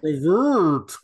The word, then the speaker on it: Brevoort: So it turns out our dear friend, Tom Brevoort, if I'm pronouncing that correctly. – Brevoort.